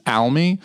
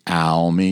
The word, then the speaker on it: Almy: Yeah, that's the number one mispronunciation of my last name. And it's not offensive to me because it's so close to the actual pronunciation, which is Almy, that if you say all me, I'm like, whatever.